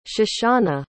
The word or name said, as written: shoshana